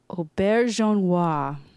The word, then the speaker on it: Auberjonois: Auberjonois.